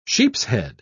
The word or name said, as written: sheepshead